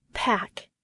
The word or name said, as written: pack